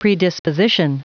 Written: predisposition

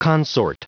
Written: consort

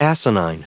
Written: asinine

